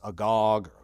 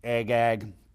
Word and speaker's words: Agag: Now, I know most people may pronounce this Agog or Agagite. I call him Agag.